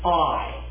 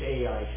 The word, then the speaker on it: Ai: That's the proper pronunciation. We need to shed this AI thing. I don't know where we got that. It's Ai, is how it's pronounced in the Hebrew and in the area, and utterly destroy it, just as he had done to Jericho, and that the inhabitants of Gibeon had made peace with Israel.